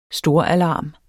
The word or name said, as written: storalarm